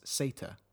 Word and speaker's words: seta: And in Icelandic, Z is pronounced as Seta.